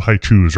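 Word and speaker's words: Hi-Chew: They're called haichus, right? – Haichu.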